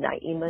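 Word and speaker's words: Naimah: And Naima is spelled N-A-I-M as in Mary, A-H.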